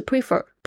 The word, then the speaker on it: prefer: Number 10, prefer, P-R-E-F-E-R, prefer, 宁愿或者是偏好，这边 prefer, 我有听到很多的同学会念成 prefer, 不是 prefer, prefer, 重音在第二个音节上面哦。